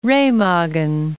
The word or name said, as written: Remagen